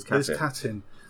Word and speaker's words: Katyn: I think it's Katyn. – It is Katyn.